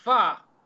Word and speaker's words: pho: Well, it's fa. – So it's more of an ah. Wow. It's more of an ah than an uh.